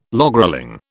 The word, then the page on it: logrolling: British English